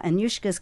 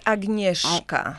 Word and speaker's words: Agnieszka: Anyushka's got the most challenging name, I must say. Is that how you say it, or do you No, actually, I say it Agnieszka.